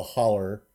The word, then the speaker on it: holler: In Kentucky, you call it a holler. – Mm-hmm. – It's spelled like hollow, though.